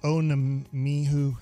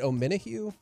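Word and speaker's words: Omenihu: I always say Charles Onemihu. I'm saying his last name wrong. Mm-hmm. I'm sorry. But is it Onemihu? – Thank you.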